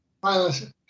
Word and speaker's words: myelination: And she has conducted a lot of research, and I guess it's in myelination. How do you say that? – Myelination.